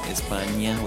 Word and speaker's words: España: Just simply remember how to pronounce Spain in Spanish as España with an ñ on it.